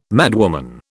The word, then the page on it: madwoman: British English